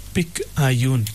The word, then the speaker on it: picayune: It's spelled P-I-C-A-Y-U-N-E. P-I-C-A-Y-U-N-E. It's pronounced as picayune.